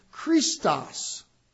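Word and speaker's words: Christos (Χριστός): In Greek, Christ, the title Christ, is pronounced Christos. And it begins with the letter chi.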